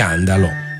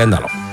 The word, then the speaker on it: Andalò: Beata Diana degli Andalò. Con l'accento sulla O. Degli Andalò.